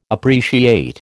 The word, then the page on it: appreciate: American English